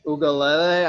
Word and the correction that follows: Tagovailoa: I don't, I, that's as close as I can get. But with Tagovailoa.